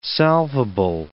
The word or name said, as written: salvable